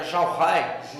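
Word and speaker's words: j'aurai: e aí no caso ficou, com, pronuncia para nós, fica Jorré. Jorré. Jorré.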